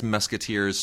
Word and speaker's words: Mesquiteers: That's Musketeers spelled like Mesquite, M-E-S-Q-U-I-T-E-E-R-S.